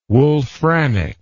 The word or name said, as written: wolframic